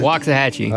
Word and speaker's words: Waxahachie: Is it Waxa or Waxa? – Waxahachie. – Well, I said it wrong.